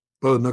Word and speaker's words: Bowden: Bowden.